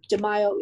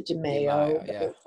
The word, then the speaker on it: DiMaio: You can find me on Facebook, Tony DiMaio, capital D-E, capital M-A-I-O. DiMaio is the correct pronunciation in Italian. It was Americanized to DiMaio. Either one is fine.